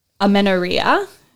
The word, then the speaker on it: amenorrhea: And when we talk about a condition like this affecting our physical health, amenorrhea, did I pronounce that correctly? – You did.